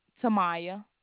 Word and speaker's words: Tahmiya: And your name is Demia? – My name, Tamaya. – Spell that for me. – T-A-H-M-I-Y-A.